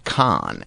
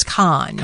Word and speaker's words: Kahan: And I'm showing that Noah's last name is pronounced Khan, and they use an example as the Mongol ruler Genghis Khan. – Okay. – Really? So Kahan or Kahan, it's Khan.